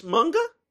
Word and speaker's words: manga: It's pronounced manga? – Yes, like mung bean.